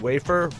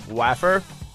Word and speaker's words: wafer: I keep pronouncing wafer, waffer. And I heard you being very polite about halfway through, and you started pronouncing it waffer too, just to humor me.